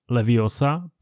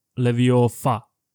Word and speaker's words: leviosa: Dozvídáme se, že je také velmi důležitá výslovnost, protože jistý kouzelník Barufio vyslovil místo leviosa leviofa a mělo to katastrofální následky.